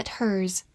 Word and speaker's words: hers: e r 字母组合发长音 er，s 发 z，hers，或者省略 h 的发音 ers。也是可以的。它是一个代词，代指它的，是一个名词性的物主代词。比如说，His eyes met hers，他的目光和他的相遇了。